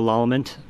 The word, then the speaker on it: Lallement: Go to my Facebook, Joshua Lalleman, for Arapahoe County Assessor. – And the last name is spelled L-A-L-L-E-M-E-N-T, right? – Right. – Okay. – And the four is F-O-R? – F-O-R, or is it the number? – F-O-R, yeah.